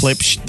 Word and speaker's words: Klipsch: I think it's pronounced yes. – Klipsch.